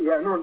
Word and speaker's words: Yanuwn: It's pronounced Yanuwn, dependent on the dialect of what galaxy you're in, the pronunciation changes.